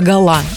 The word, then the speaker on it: гала: Соответственно, как и все французские слова, ударение на последний слог «гала».